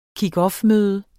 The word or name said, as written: kickoffmøde